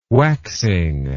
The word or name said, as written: waxing